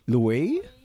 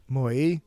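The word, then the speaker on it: muối: Mui. It's like meow. Mui. It's an M. – Yes, M. It's spelled M-U-O with a caret. And a little slashy going up to the right, and an I.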